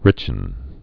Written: richen

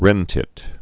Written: wren tit

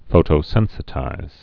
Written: photosensitize

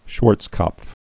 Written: Schwarzkopf

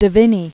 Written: De Vinne

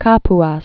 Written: Kapuas

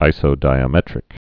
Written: isodiametric

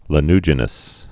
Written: lanuginous

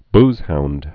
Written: boozehound

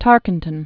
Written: Francis Asbury Tarkenton

